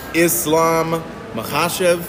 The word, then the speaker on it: Islam Makhachev: Khabib Jr. Islam Makhachev is the proper way to pronounce it.